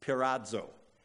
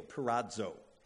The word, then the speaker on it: peirazo: It's pirazo, P-E-I-R-A-Z-O, pirazo.